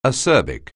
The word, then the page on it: acerbic: anglais